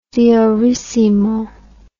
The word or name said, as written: diorissimo